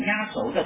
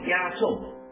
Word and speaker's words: 压轴: 这个词就是压轴，相信大家都听到过这个词被念成压轴的读音，其实是错的。这个词正确的应该读作压轴，而且大家用到这个词的时候，往往是用来指最后一个节目或最后出场的重要人物，比如压轴戏、压轴出场等。